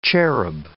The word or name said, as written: cherub